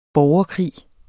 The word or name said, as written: borgerkrig